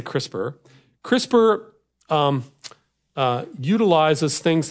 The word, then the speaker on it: CRISPR: So it's pronounced CRISPR like the CRISPR drawer in your refrigerator.